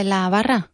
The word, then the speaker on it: la barra: How do you say the bar counter in Spanish? – La barra.